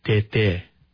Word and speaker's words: Tete: It's not pronounced correctly. – Right. – It would actually be pronounced Tete, right? – Yes, you're right.